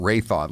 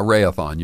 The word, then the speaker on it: Raytheon: It's said Ray-a-thon, like it's a marathon. Ray-a-thon. – Did he say Ray-a-thon? I kind of got Ray-thon. Let's hear it again. – And Ray-a-thon. – Yeah, Ray-a-thon. You're right. Ray-a-thon. Rhymes with marathon.